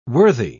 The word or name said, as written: worthy